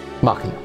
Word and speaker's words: Machina: I thought it was Vox Machina. Is that how you pronounce it? – Yes. Machina.